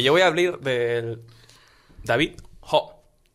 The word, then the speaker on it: David Ho: O sea, es David Ho. – No, David Ho, sí.